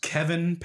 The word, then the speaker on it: Kavan: I believe it's pronounced Kevin Peppersack, but it's spelled K-A-V-A-N.